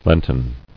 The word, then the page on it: Lenten: Lent·en